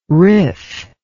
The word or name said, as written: rif